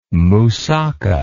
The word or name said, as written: mussaka